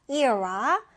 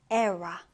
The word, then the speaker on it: era: 이 단어는 발음 두 가지 가능한데요. era 또는 era 둘 다 가능합니다.